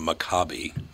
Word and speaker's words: Maccabees: Oh, it's Maccabees. – It's Maccabees. You're absolutely right. Or some people pronounce it Maccabi, but close enough.